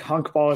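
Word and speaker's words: honkbal: Honkball is how you say baseball in Dutch.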